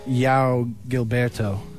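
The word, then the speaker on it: João Gilberto: His name is like Yao Gilberto. That's what I want to say because it's J-O-A-O. And it's got an accent on the A, but I don't know what that does.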